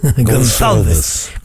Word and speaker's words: Gonçalves: Gonçalves.